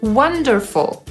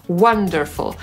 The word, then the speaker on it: wonderful: Wonderful. I właśnie wspaniały, cudowny, z co też pochodzi po polsku od słowa cud, też właśnie wymawiamy przez A. Wonderful.